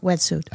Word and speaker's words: Wetsuit: Heißt es Wetsuit? Ja. Habe ich das richtig ausgesprochen? – Ja, Wetsuit.